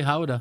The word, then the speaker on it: Gouda: And do you pronounce it Gouda or Gouda? – Depends on who you are. – Right. – But the Dutch say Gouda. – Right. It's a cheese. Anyway, yep. – We'll call it Gouda for you people that aren't Dutch that are listening here.